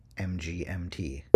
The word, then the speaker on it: MGMT: Although they were at one point in the past known as the Management, they do in fact pronounce their name MGMT.